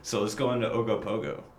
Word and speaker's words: Ogopogo: Ogopogo. You got to say it like a Canadian. Ogopogo.